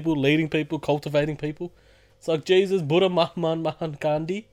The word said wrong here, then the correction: Mahatma: What's his first name? Mahaman, Mahama. How do you say his first name? – Mahatma? I think it's Mahatma. – Yeah, Mahatma. Gandhi.